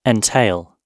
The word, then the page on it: entail: British English